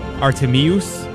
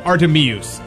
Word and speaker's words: Artemius: The Feast of Saint Artemis. Actually, I think it's pronounced Artemius, but Artemis sounds cooler. So, whoops. Saint Artemius.